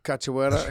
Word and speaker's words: Cachoeira: Cachoeira.